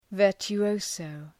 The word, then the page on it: virtuoso: ,vɜ:rtjʋ’əʋsəʋ